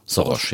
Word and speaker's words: Soros: Ich spreche von George Soros oder Soros. – Wie spricht man? Er kommt aus Ungarn. Er ist gebürtiger Ungar. – Soros, ja.